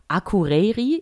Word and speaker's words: Akureyri: Es gibt nämlich in Island und zwar in Akureyri.